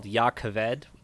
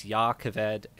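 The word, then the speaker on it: Jakaved: It's called Yakaved. It's also spelled with a J. So both J's are with a Y. So it's Yakaved and Jasnah.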